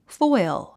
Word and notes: foil: fɔ́il; フォイゥ

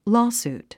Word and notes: lawsuit: lɔ́ːsùːt; ロースートゥ

